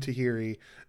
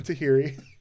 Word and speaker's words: Theory: Also, Johnny Gargano has a weird kind of cult group, The Way, with Austin Tahiri and Candice LeRae, his wife. – Thank you for pronouncing Austin Theory's name the way we pronounced it at Evolve shows. – That's right, Austin Tahiri.